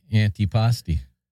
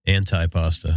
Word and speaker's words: antipasti: Yeah, like antipasti. – Antipasta. – Antipasta. – I'll never forgive you for that. – Somebody we were talking to, he actually thought that was the pronunciation, antipasta.